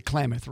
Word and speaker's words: Klamath: For the first time in over 100 years, a salmon has been spotted swimming up the Klamath River. I think that's how you say it. – It is. It's part, like you could say, it's Klamath Falls, but it's the Klamath River.